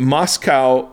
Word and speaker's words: Moczko: And Moscow, is spelled Moczko. Moczko.